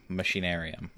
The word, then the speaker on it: Machinarium: Machinarium.